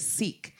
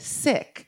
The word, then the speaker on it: Cikk: It was Sikk. C-I-K-K. That's how we pronounced it, but everybody said sick.